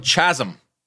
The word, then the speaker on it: chasm: It's still a chasm. – Like, chasm. – Like that word. Fuck. – All right. It's spelled chasm, but it's how you pronounce it. Chasm.